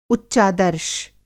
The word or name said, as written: उच्चादर्श